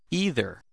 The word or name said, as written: either